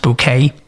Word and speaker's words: Bouquet: Of course, the star was Patricia Rutledge as Hyacinth Bouquet, who insists it's pronounced bouquet, and Clive Swift as her long-suffering husband, Richard.